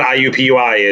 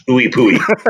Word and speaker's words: IUPUI: Excuse me, it's not IUPUI. It's Ooey Pooey. – Ooey Pooey, yeah. The correct pronunciation.